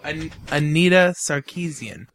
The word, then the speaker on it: Anita Sarkeesian: Okay. Sarkeesian? – Yes. Anita Sarkeesian. – What did I say? – Anna Skenesian? – Yeah, that's not it. – Anna talk into the microphone and say it, please. – Oh, Anita Sarkeesian.